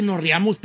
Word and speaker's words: reíamos: No, nos reíamos. – Acento en la I, viejo.